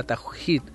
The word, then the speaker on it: tawhid: Tiene dos partes esta fórmula, que es, la primera es la unidad, la tawhid, como dicen ellos, o la tawhid, como se pronuncia.